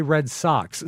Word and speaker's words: Red Sox: And is it a Red Sox or a Red Sox, since they spell it with an X? – It's a Red Sox.